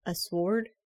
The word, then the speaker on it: sword: I think I had enough to make a sword. Sword? – Well... – And just totally added a weird pronunciation to that. Don't mind me. I pronounced the W for some reason.